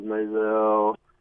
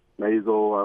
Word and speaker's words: Maisel: Marvelous Mrs. Maisel, Maisel, I've been saying it wrong. Now that I've seen it, I know how to say it.